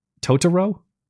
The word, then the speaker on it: Totoro: My wife told me I pronounce it wrong. It's Totoro.